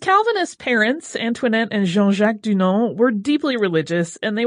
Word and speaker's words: Dunant: I'm not sure why that is the case, but in any case, we're writing it with the Y, but using the French pronunciation right out of the gate, just to confuse you. – Jean-Henri Dunant was born in Geneva, Switzerland on May 8, 1828 into a wealthy family, and he was the first of five children.